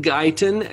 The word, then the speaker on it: Guyton: It's called Ideal Me by Glenn Guyton, and that's spelled G-U-Y-T-O-N. – G-U-Y-T-O-N. Guyton. Very simple.